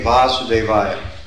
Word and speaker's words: Vasudevaya: So what Prabhupada is doing here is he's looking at Om Namo Bhagavate Vasudevaya. That word is with a long A.